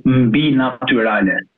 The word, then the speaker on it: Mbinatyrale: It is supernatural, and in Albanian it's mbi naturale.